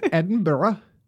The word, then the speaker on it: Edinburgh: A woman in Edinburgh, which I always hate seeing written because there's just extra syllables that they add into this town in Scotland. – It's just begging you to say it wrong. – It's just begging me to say Edinburgh, but no, it's Edinburgh.